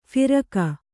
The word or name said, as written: ಫರಕ